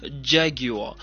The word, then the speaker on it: Jaguar: We are talking about Jaguar Motors, or should I say in the British pronunciation, Jaguar.